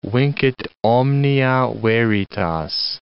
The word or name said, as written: vincit omnia veritas